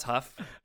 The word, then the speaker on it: Hough: I've accidentally referred to him as Derek Hughes many times, just in conversation with like Tiana, but I believe it's pronounced Huff.